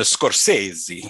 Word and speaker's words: Scorsese: This week, as we cover our fifth recommendation film of Recommendation Month, 1990s Goodfellas by Martin Scorsese, I think is how you pronounce his last name. – That's it exactly. – It's either Scorchese or Scorsese, but This is like a one-hit wonder, right?